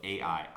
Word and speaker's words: Ai: It's kind of Jericho in, I can never say it right, it's spelled Ai, I think is how you might say it, are the two cities that they're supposed to conquer.